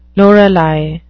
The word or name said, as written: lorelei